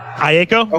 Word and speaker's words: Aiko: Am I saying that right? Gene. You, I think Aiko? you are. Aiko. Okay. Aiko.